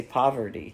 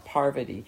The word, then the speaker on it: Parvati: I have to say her name correctly because it's hard. I always want to say Poverty. – Yeah, Parvati.